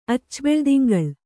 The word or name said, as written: ಅಚ್ಚಬೆಳ್ದಿಂಗಳ್